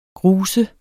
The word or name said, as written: grus